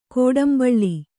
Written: ಗೌಡುಂಬಳಿ

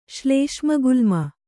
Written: ಶ್ಲೇಷ್ಮಗುಲ್ಮ

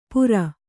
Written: ಪೂರ

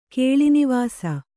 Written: ಕೇಳಿನಿವಾಸ

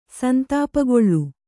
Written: ಸಂತಾಪಗೊಳ್ಳು

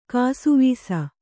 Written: ಕಾಸಾವೀಸಿ

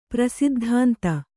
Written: ಪ್ರಸಿದ್ಧಾಂತ

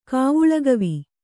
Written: ಕಾವುಳಗವಿ

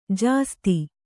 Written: ಜಾಸೂದು

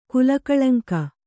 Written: ಕುಲಕಳಂಕ

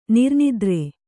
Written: ನಿರ್ನಿದ್ರೆ

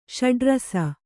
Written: ಷಡುರಸ

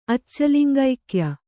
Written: ಅಚ್ಚಲಿಂಗೈಕ್ಯ

